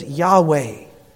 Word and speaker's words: Yahweh: or that would have been pronounced by the Hebrews as Yahweh.